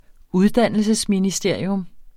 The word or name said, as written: uddannelsesministerium